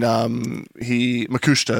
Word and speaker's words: Makushita: And Makushita is how they say it, sorry.